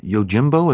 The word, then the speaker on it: Yojimbo: Yojimbo.